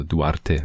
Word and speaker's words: Duarte: geklärt, denn äh, ich spreche ein bisschen Spanisch, also Duarte müsste es dann wohl sein.